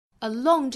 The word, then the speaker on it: along: Along 这个词美音读成 along，上下的开口都会更大、更开、更松。